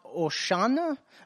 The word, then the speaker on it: Hosanna: Now, I'm not big into the Greek, but here's what Hosanna means. It actually is pronounced Oshana, but it actually means save, please, or save us.